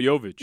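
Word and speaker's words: Jović: So you got to give Jovic, is that how you say it? Jovic? – Yeah, Nikola Jovic.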